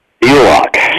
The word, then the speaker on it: Bulach: It's Dr. Clete Bulach.